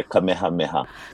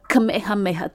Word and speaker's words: Kamehameha: I spent a lot of time in Molokai and learned about King Kamehameha. I'm not sure if that's the exact pronunciation, but... – There's an H in there, Kamehameha. – Kamehameha. Thank you so much.